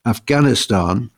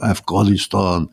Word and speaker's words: Afghanistan: Afghanistan, he says, without clearing your throat in the middle, Afghanistan.